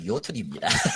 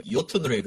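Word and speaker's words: Jötunn: 철자 어려운 반데 북유럽, 북유럽, 북유럽 그 발음식이라 조가 아니에요. 요톤으로 읽어야 돼.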